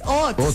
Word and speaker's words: haut: Es que es H-A-U-T. ¿Eso no es francés? Hot. Sí, eso es francés. Pues el colegio me enseñaron que era francés, sí. – Hot. Sí, hot. – Pero no la pronunciación. – Es que como lo digo, hot.